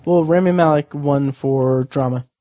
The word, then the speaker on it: Rami Malek: Rami Malek, yeah.